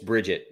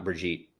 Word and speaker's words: Bridget: And I guess it's Bridget, not Brigitte, but yeah.